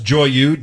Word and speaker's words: Joyeux: I think it's pronounced Joyeux, J-O-Y-E-U-X, which means happy in English.